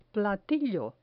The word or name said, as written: platillo